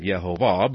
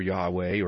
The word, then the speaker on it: Yehovah: I obviously have landed on the pronunciation of Yehovah, but others use Jehovah or Jehovah or Yahweh, or some don't like to use it at all, but you can at least recognize the Yod-Heh-Vav-Heh.